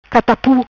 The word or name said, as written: Catapu